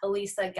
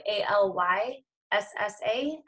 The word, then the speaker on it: Alyssa: So at Alyssa Galios. And Alyssa is spelled A L Y S S A, and then Galios is G A L I O S.